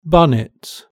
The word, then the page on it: bonnet: British English